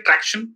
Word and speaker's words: Tracxn: Tracxn? – Perfect.